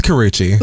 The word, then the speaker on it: Karrueche: With Karrueche. – Look at... Is that how you say it? – Yeah, that's how you actually say it.